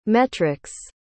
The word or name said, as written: metric's